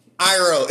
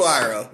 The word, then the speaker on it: Iroh: This man, Ryan, who cannot pronounce milk correctly, also cannot pronounce Iroh. Iroh. It's the first thing Zuko says in the first episode. Uncle Iroh. It's his first fucking line, and you fucked it up.